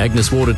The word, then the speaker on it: Agnes Water: Down to Agnes Waters. – Mate, it's pronounced Agnes Water.